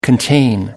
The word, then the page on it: contain: American English